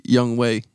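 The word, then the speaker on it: Younghoe: No, it's not. It's actually Young Way. – That's not what it is. Young Way. – Young Way Koo.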